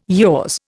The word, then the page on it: yaws: Indian